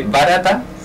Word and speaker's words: Barata: que es un taller de Barata. – Sí. – Así se pronuncia, ¿no? – Así se pronuncia.